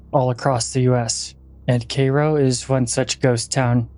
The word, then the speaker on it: Cairo: Oddly enough, though, they pronounce Cairo as Cairo, C-A-Y-R-O, and not Cairo as in the Egyptian city, which is annoying to me for some reason, because it's still spelled like the Egyptian Cairo.